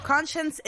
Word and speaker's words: Konshens: That song is called Same So by the artist, I want to say Conscience. Conscience is spelled K-O-N-S-H-E-N-S.